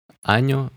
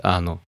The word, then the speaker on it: año: Аню в переводе год. А, тут, кстати, главное не произнести или не написать «Ану», иначе вы можете прослыть грубым пошляком.